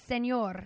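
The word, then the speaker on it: Señor: No, it's senor.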